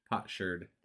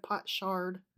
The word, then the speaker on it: potsherd: Potsherd. Instead of pot shard.